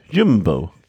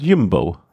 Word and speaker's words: Jumbo: Der meinte so, man musst du aussprechen, U wie Ü, Jumbo.